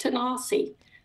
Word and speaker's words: Tanasi: And it's spelled Tanasi, and it's pronounced Tanasi, which is the Cherokee word for Tennessee.